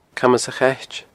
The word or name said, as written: Camas a' Chait